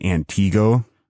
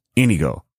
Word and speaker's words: Antigo: A listener by the name of Sasha pointed out to me, she apparently grew up near Wausau, and I've been saying Antigo, and it's not Antigo, it's Antigo.